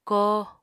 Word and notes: kóo: Apatani

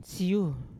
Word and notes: syu: Yawa

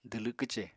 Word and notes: dwlw kwce: apatani

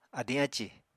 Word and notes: adiñ ácì: Apatani

